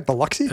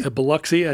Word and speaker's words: Biloxi: Biloxi? – Biloxi, I think is how they say it.